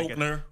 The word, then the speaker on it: Koechner: Koechner.